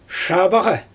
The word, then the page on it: ʃabaɣa: Xyzyl